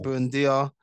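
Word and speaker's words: Buendía: Buendia.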